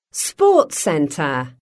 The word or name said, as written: sports centre